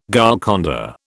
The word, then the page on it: Golconda: British English